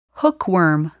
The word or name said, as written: hookworm